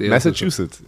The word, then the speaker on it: Massachusetts: Massachusetts, ja, ja. Habe ich richtig ausgesprochen. Ja, du kannst McNamara nicht sagen.